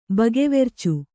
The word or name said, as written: ಬಗೆವೆರ್ಚು